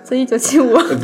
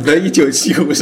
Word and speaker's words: The 1975: The 1975 The 1975 是吧？